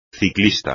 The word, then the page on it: ciclista: espagnol